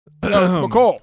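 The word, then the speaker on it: Mecole: It's McCall. Go look at the pronunciation guide. – McColl. – I've got it right here. It says it in here. – McColl. Thank you. Bam. McColl. Score one for me.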